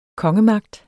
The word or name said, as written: kongemagt